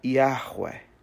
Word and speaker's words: Yahweh: Então, assim, o primeiro H é aspirado, Yahweh.